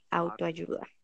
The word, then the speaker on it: autoayuda: I don't remember the word in English, but in Spanish it's autoayuda.